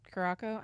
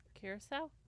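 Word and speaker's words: Curaçao: It says white rum, fresh lime, orange, curacao. I never say that right. – Curacao.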